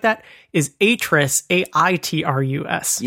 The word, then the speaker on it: Atrus: It is pronounced Atris. – Oh, damn it. I did it again. – The one in the game is Atris, A-T-R-U-S.